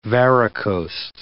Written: varicosed

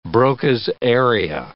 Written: Broca's area

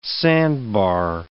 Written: sandbar

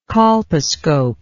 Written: colposcopist

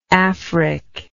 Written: afric

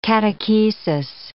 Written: catechesis

